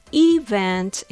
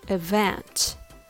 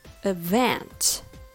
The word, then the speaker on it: event: Now, I've heard this word quite often mispronounced with the stress on the wrong syllable. I heard many say event instead of event. The stress should be on the second syllable, so it's event.